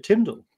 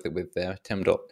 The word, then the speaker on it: TMDL: So it didn't take very long for the first person to say TIMDL. There we go. It's definitely easier to pronounce.